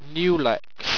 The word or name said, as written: nuilex